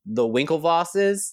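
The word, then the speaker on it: Winklevoss: But the Winklevosses, am I saying that right? – Yeah.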